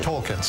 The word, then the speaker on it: Tolkien: Is how do you say his last name? – Tolkien. – I thought it was just Tolkien. – Tolkien, sorry.